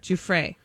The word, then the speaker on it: Giuffre: Which, by the way, I think we now know how to say her name. – Yeah, how do you say it? Giffrey. – Giuffre. Yeah, I watched a bunch of interviews over the weekend, and I'm assuming that's how she pronounces it, because every interview I listened to, they were like, Giuffre.